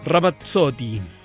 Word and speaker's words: Ramazzotti: Ramazzotti. Empecé a escuchar la canción y empecé a pensar si Eros Ramazzotti, Eros, es el nombre de Eros Ramazzotti. Ramazzotti, ahí lo estoy pronunciando correctamente.